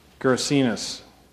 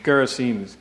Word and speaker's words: Gerasenes: Gerasenes. I know I pronounced that wrong. I can't even read. Help me out. What is it? – Is it Gadarenes? Gerasenes. The Gerasenes.